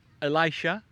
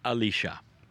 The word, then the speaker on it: Elisha: And that man has a name, and it's pronounced many ways. In some cultures, it is Elisha, in some it is Elisha.